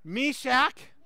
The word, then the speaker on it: Meshach: I did a little research, and the correct pronunciation, repeat after me, Shadrach, Meshach, Abednego. Okay, that the third one's the one we always get wrong, but Abednego, and the fourth is Daniel, but we're going to focus on the first three.